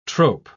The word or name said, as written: trope